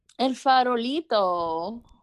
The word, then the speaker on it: El Farolito: okay, and then next up you have El Farolito. Did I say that right? El Farolito. Okay.